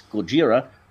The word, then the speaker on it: Gojira: Same with the final letter, since Japanese speakers would pronounce an R sound as an L, that final letter might be pronounced as La. So just as much as it's Gojira, the title of the film and name of the character is also Godzilla.